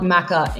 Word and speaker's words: Maca: There was two Maddies like 10, 12 years ago, and so I got Maca instead. So Maca.